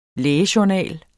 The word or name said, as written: lægejournal